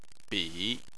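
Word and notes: bi: tone 3

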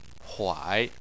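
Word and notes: huai: tone 3

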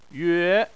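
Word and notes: yue: tone 2

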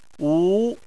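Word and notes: wu: tone 2

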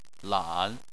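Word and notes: lan: tone 3

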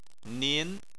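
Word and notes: nin: tone 2

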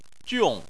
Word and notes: jiong4: chinese

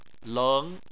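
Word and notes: leng: tone 2

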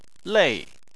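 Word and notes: lei: tone 4

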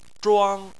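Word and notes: zhuang: tone 1

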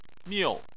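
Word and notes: miu: tone 4

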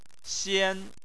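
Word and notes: xian: tone 1

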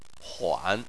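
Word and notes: huan: tone 3

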